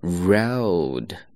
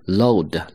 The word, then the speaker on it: road: 第一个，道路这个单词非常简单，它读作 road，正确的发音是这样，中间是一个双元音。那么双元音发音的时候呢，一定要饱满一些，o。其实很多同学发的时候呢，把这个音会发为 load，你知道为什么会发错吗？